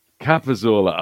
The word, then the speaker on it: Capozzola: Capozzola.